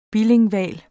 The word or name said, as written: BILINGVAL